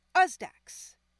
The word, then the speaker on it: Osedax: Uzdax.